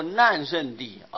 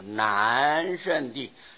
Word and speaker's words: 难胜地: 现在不能念作难胜地啊，必定要念作难胜地，很难胜过他啊，很难胜过第五地的这个境界，所以叫做难胜地。